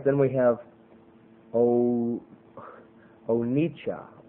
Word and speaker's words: onycha: Now, and onyca, as it's pronounced, O-N-Y-C-H-A, onyca, is the shell of a species of mussel found in the lakes of India, which when burned emits a musky odor.